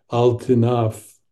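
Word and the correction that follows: lotinaf (לא תנאף): lotinaf, I'm sorry, but is not English.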